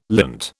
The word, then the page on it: lint: British English